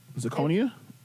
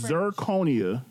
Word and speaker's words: zirconia: Zirconia? Why you add an R? – I wanted to sound fancy when I said it. – By pronouncing it wrong? – It's zirconia, first of all.